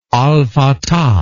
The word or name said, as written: al fatah